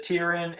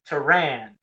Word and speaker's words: Tyrion: Like you said, Tyrion, and then Lady Valtira always calls him Tyrann.